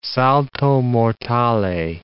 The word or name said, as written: salto mortale